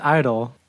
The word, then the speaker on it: (G)I-DLE: Yeah, so today we will be going over the EP I Burn by the group I-DLE. Yes, it is pronounced I-DLE, but it is spelled open parentheses G, close parentheses I dash D-L-E.